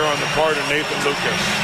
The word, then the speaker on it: Lukes: Lucas spells his name L-U-K-E-S. It is pronounced Lucas.